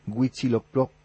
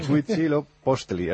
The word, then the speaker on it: Huitzilopochtli: Nos dice Arturo Blas Fonseca, en el foro me llama poderosamente la atención, que la pronunciación correcta en castellano es Huitzilopochtli. Bueno, ya está, pues. Huitzilopochtli.